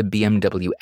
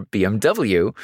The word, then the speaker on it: BMW: And like I said before, the W in German is pronounced as a V, so the mehr denn je, BMW, super catchy, but it would only appear sporadically and not so often.